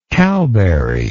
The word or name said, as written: cowberry